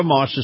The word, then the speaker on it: Maus: Moss is spelled M-A-U-S, and if you love the feel of a high-end SUV, then you're going to love Moss Acura.